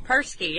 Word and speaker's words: Perski: Perski, yeah.